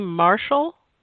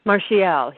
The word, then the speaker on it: Martial: Does he say his name Marshall? – Martial. He's French.